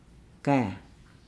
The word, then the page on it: gaa: Nukuoro